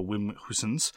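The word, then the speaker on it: Wim Goossens: I apologize in the past for having tried to pronounce it in a German way. My understanding is that it is to be pronounced in a Dutch way, so Wim Hoosens.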